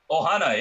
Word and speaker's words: Ohana: I think absolutely, and I totally relate to what you're saying about the your, your how do you is it Wahana? Is that how you pronounce it? – Ohana, yeah. Ohana, because okay, okay, so yeah, Ohana.